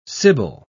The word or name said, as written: sibyl